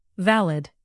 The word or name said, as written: valid